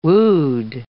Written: wood

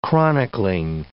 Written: chronicling

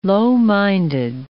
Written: low-minded

